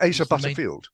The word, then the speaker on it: Asa Butterfield: Yes, isn't it Asa Butterfield? – Oh, is it Butterfield? Oh, I'm sorry. Asa Butterfield.